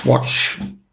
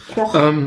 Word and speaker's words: Quach: Abquatsch oder wie Quach. – Genau. – Wie Quatsch ohne TS. – Quach.